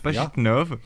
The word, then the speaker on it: Pajitnov: Pajitnov, jeune chercheur de l'Académie des sciences de Moscou, achète un jeu de société constitué de pentaminos.